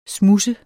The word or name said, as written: smuds